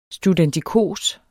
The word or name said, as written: studentikos